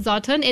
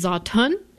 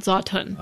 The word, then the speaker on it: Zatan: Uh, my dad sometimes tries to explain it to us, and he's like, "It's Zatan." It's not Zotun.